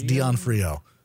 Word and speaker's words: D'Onofrio: Is that how you pronounce his name? D'Onofrio? – Sure. – D'Onofrio? – Yes, D'Onofrio. That's it. You nailed it.